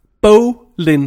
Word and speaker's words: Boleyn: Bolin. Det er sådan, man udtaler det. Hvis du skulle være i tvivl.